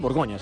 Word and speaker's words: Borgoña: Y el IDK visita al Charnay Borgoña en jornada Borgoña, ¿no? Borgoña se dice.